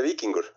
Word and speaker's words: Víkingur: Se dice Víkingur. Así como se... Es que el islandés es curioso, que el islandés similar al español, como lo ves escrito, así se pronuncia. Lo que pasa es que son... letras difíciles, ¿verdad? Combinaciones difíciles, pero así se lee.